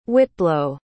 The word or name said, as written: whitblow